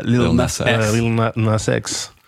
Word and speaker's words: Lil Nas X: J'ai compris Nina Sex et je disais mais qui c'est Nina Sex? – Non, pardon. – Lil Nas X. – Pardon, je le prononce mal, c'est ça. Lil Nas X.